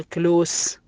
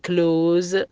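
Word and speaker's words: close: يوز يستخدم معناها، نوز معناها الأنف، الكلمة اللي بعدها كلوز ما تنطق كلوس بل تنطق كلوز، الكلمة الأخيرة تشيز، هنا نطقنا حرف الإس بحرف الزاء، أتى قبله وبعده حروف متحركة.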